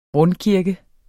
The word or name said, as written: rundkirke